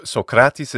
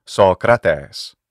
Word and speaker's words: Socrates: So Socrates is the modern Greek pronunciation. Jordan did it perfectly. Ancient pronunciation, say classical Attic pronunciation, Socrates.